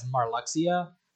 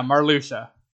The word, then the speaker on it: Marluxia: For the longest time, I said his name as Marluxia, but apparently it's pronounced... – That's what it looks like, is Marluxia. – Yeah, apparently that X is pronounced as more of a sh, so it's more...